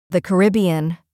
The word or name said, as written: The Caribbean